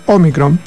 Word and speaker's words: Omicron: Omicron, con acento en la O,